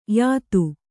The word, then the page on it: ಯುತಿ: Kannada